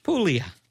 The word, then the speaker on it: Puglia: Puglia.